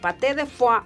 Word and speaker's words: Paté de Foix: Así se llaman, como se lo estoy pronunciando, Paté de Foix, así búsquelos en cualquier plataforma.